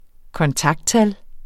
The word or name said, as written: kontakttal